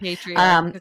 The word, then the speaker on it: patriarchal: Patriarchal. I'm adding like five syllables in that. – Patriarchal authority.